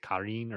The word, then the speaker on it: Karin: I'm going to keep going with Corin, but the spelling is Karin, so that's what you get. – Yeah, I think they pronounce it Karin or something like that, but we're going to call him Corin.